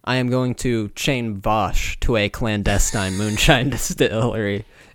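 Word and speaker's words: Vaush: I learned recently that I've been pronouncing Vouch's name wrong. – Honestly, keep fucking it up. – It's supposed to be Vosh.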